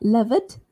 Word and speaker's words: loved: R-I-G-G-E-D, rigged. So make sure you say the D sound at the end. L-O-V-E-D, loved. So not loved, loved. S-O-O-T-H-E-D, that's soothed, soothed. What about this word? J-U-D-G-E-D.